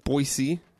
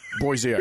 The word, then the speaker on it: Boise: To Idaho, Boise, in fact, about six hours from here. – Boise. – Bois. – This is not how you pronounce it. Boise, Idaho. It's a city.